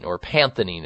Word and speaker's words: panthenine: called panthenine is how it's actually prescribed. And it's spelled PANTHENINE, panthenine.